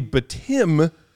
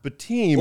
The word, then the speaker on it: Bitim: It's actually Bitim, not Bitim.